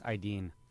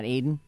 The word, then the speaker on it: Aidin: His name is pronounced Aydin. – Aydin? – Mm-hmm. – Not Aiden? – No. – I like Aiden. – A-I-D-I-N.